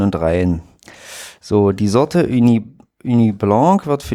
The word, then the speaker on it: Ugni: Oh, also UGNI geschrieben. Ich will es jetzt hier nicht komplett falsch. Wie spricht man das? UG? – UGNI? – Ja, Uni.